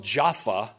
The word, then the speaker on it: Jaffa: This is just, we call it, it's pronounced here in Joppa, but you'll see here in just a minute, it's spelled Jaffa in Israel, J-A-F-F-A.